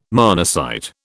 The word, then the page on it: monocyte: British English